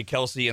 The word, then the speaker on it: Kelce: It's pronounced Kels. – Nope. – But their dad got so tired of correcting people, he just let them call everybody Kelce.